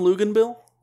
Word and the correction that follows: Luginbill: Tom Luginbill? – I knew it was Tom Luginbill, whatever.